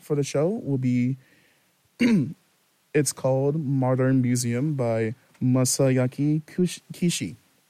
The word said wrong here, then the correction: Masayuki Kishi: I butchered her last name a little bit. That's my apologies. Masayuki Kishi. Modern Museum.